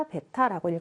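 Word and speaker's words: 베타라고 (β): 알파, 베타라고 읽어주시면 되고, 우리 방정식에서의 근을 표현하는 그리스 소문자다라고 그냥 생각하시면 됩니다.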